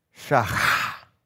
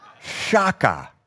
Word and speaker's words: shachah: This word in the Hebrew is pronounced shachah, shachah. Now you got to say it like a good Jew, shachah, with a. But if you don't want to say it like a good Jew, you can say it like a good American gringo, shaka.